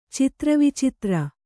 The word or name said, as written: ಚಿತ್ರವಿಚಿತ್ರ